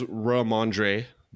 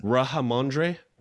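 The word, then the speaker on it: Rhamondre: Who says Ramondre? Did I say it properly enough? – Not even close. – I don't think so. – Just say Stevenson. Mr. Stevenson. – Ramondre? – That was good. That was better.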